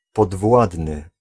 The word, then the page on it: inférieur: [ɛ̃.fe.ʁjœʁ]; France